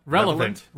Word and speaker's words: relevant: I'm about to mispronounce it. Revelant. Relevant. – Relevant. – Revelant. See, I don't know. – Relevant. – Relevant. But I don't think revelant. I don't know if that's even a word.